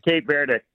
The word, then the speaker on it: Cape Verde: Either way, you said it right. That's the ink. It's kind of like Turkey, Turquia. – Yeah. – They in Spanish, it's Cabo Verde. – Cabo Verde. – Cabo San Lucas or whatever in Mexico. Cabo Verde. But okay. The English pronunciation is Cape Verde.